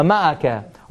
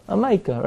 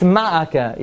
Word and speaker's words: ma'akeh: Or if you want to sound yeshivish, how do you say it? A mica, right? I don't know where the yud comes from, but that's how you say it. If you want to sound like, you know, you want to sound like a lamdan, also like an amaretz, but it's ma'aka, yeah, ma'aka.